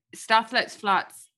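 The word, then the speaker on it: Stath Lets Flats: Staff Let's Flats, on Channel 4 in the UK. Am I saying that correctly? – You are. Staff Let's Flats is actually my brother's show, but even though I'd love to take credit for it, but I'm in it.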